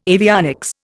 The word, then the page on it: avionics: Indian